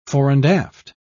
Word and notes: fore-and-aft: British English

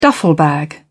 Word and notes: duffel bag: British English

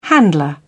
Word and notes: handler: British English

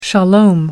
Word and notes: shalom: American English

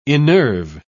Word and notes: innerve: British English

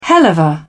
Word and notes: helluva: British English

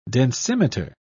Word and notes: densimeter: British English

